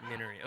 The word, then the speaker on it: Minari: I will not claim to have the proper Korean pronunciation, but the one that you heard is definitely wrong. – Minari. Okay, so we're going to go with Minari, this Lee Isaac Chung film that A24 brought into competition.